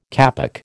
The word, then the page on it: kapok: American English